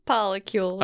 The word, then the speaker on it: polycules: But no matter how many members are in a polyfidelity unit, polyfi units are called polycules. – Polycules. – I know, it's a really cute word. – Say it like Neil deGrasse Tyson would say molecules.